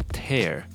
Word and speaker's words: tear: 那不过它当动词的时候念作 tear，是有拆除或是扯开的意思。